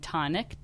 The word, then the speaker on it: Tonik: And the bar was going to be named Tonic, T-O-N-I-K, accent on the I.